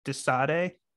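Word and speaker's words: De Sade: like on songs like Vermillion or like Dead Memories, and like that's what they did with, I think it's Desade is how you pronounce the song.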